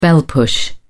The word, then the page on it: bell push: British English